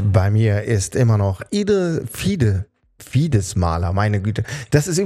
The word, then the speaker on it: Fides: Also die Lateiner sagen sogar Fides auf der zweiten Silbe.